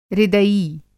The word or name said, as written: हृदयी